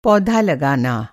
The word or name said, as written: पौधा